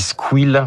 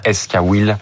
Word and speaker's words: Squil: On dit Squil, mais pour que les auditeurs comprennent mieux, c'est mieux de dire Esquil. – Voilà, ça s'écrit avec un S et un K et Will comme roux.